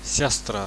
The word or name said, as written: siastra